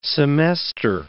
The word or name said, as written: semester